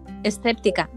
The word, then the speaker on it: escéptica: Eh, lo que te dije, empecé muy escéntica. se dice escéntica, ¿no? no, sé si lo he dicho bien. – Escéptica. Exacto.